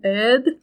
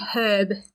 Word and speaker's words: herb: I know in other places they say an herb and an herbalist, but it's just because of how different people talk. We pronounce the H, so I will. So I'll be saying herb.